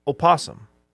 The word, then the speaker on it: opossum: opossum. – Ooh, you pronounced the O. – I did. – Huh. – What are you supposed to do with that O? – I think it's optional, but sometimes I do it for dramatic effect.